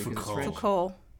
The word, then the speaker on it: Foucault: I don't think you don't pronounce a T, do you? – Foucault.